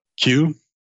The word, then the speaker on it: Quuu: You know, one of the ones that I like right now is Q. You heard of Q? – No, what is it? – It's spelled Q-U-U-U.